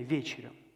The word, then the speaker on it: вечерю: Итак, во втором стихе мы читаем, там приготовили ему, то есть Христу, вечерю, или правильное ударение, вечерю.